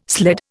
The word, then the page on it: sled: Indian